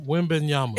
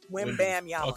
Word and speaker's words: Wembanyama: If you look at how it's spelled, okay. It's just where you break it up. – Wham bam. – Nah, win. – Wim Bam Yama. Okay, like it looks exactly how it sounds.